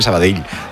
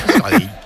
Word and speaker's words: Sabadell: El cachondo de Kiki Lisa me dice, pronuncias muy bien Sabadell. Dice, mis respetos. – Sabadell. A mí me lo decían mucho cuando estaba en Primera Federación, porque yo también decía Sabadell, que es como se dice.